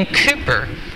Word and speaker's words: Cowper: So I also read this week a very short biography about a man named William Cooper. Maybe some of you have heard of him. If you ever looked him up, his last name looks like Cowper. It's spelled C-O-W-P-E-R, but it's pronounced Cooper, William Cooper.